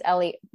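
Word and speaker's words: Elie: I can never figure out how to pronounce his first name, whether it's Ellie. Thank you. I always go back and forth on that.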